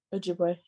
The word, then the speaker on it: Ojibwe: How did I say it before? Ojibwe? – Yeah. – You fussed at me a good bit over that one, so I had to work on it.